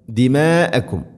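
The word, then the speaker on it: ദിമാഅക്കും (دِمَاءَكُمْ): ലാ തസ്ഫികൂന ദിമാഅക്കും, ദിമാഅക്കും മദ് മുത്തസിലാണ്, നിർബന്ധമായിട്ടും നാലോ അഞ്ചോ ഹർക്കത്തിൻ്റെ ഖദറുകൾ നീട്ടണം. വലാ തുഖ്രിജൂന അൻഫുസക്കും, അൻഫു നൂനിനെ അവ്യക്തമാക്കി മണിക്കണം.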